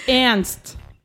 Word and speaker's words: Ernst: Pero el representante, el embajador principal de este reloj es un nadador de expedición llamado Ernst Bromes. Ah, bueno, es en alemán, entonces ahí les fallo un poquito con la pronunciación. es Ernst.